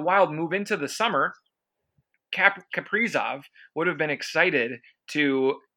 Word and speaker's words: Kaprizov: Kirill Kaprizov, or Kaprizov, I believe is how they say it in Russia, is supposedly coming to the Minnesota Wild.